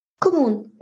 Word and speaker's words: Kommun: Und zwar ist das die Gemeinwohlstiftung Kommun. Oder wie spricht man das richtig aus? – Kommun, genau richtig. Genauso.